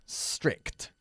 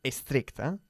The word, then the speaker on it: strict: Some of my old school teachers were very strict. No se puede decir estricta. Cuidado con la pronunciación.